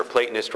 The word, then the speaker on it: Platonist: But remember, Platonist rhymes with Satanist.